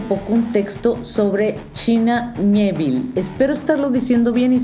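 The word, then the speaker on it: Miéville: El apellido es un apellido francés. Entonces se mezcla ahí la pronunciación del inglés británico con el acento este particular que tiene en la E, entonces se pronuncia Mieville.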